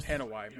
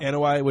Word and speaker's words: Anoa'i: the Anoa'i family, which is the Anoa'i, yeah. – Anoa'i, which is the Rock's family, Roman Reigns.